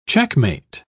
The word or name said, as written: checkmate